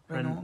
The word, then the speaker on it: Renaud: so it's R-E-N-A-U-D. I think, like, if we're going French, like Renault. – Yeah. – It's like Renaud. – Renaud.